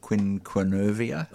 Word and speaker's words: quinquenervia: So it's Melaleuca quinquevera. So let's have a go. – Quinquenervia. – Oh, quinquenervia. I always drop a few syllables.